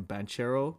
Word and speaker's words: Banchero: Banchero.